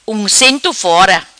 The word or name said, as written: Uncento fora